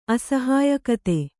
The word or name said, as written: ಅಸಹ್ಯೋಕ್ತಿ